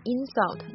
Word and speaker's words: insult: 很多动词呢，如果它的动词名词长得一样，那么它的发音重音部位会改变。比如说 insult，它的动词呢重音在这个 su 上，但它名词会在前边 insult。